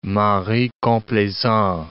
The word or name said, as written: mari complaisant